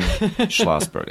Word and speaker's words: Schlossberg: And this is Hayden Schlossberg. I don't think I even pronounced my name right there. Hayden Schlossberg, okay. – It's Schlossberg. Okay. Schlossberg. – It's always a difficult one. There's that weird silent C.